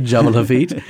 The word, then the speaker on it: Jebel Hafit: Am I saying that right? – Jebel Hafit. Yeah.